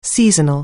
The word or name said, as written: seasonal